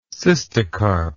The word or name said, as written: cystocarpic